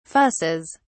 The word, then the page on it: fusses: British English